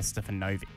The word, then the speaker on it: Stefanovic: Now, Karl Stefanovic, is that how you say it? – Karl Stefanovic. – Novik. Apparently, I didn't know how to pronounce it.